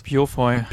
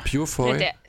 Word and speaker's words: Purefoy: Purefoy. – Purefoy.